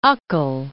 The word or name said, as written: Ukkel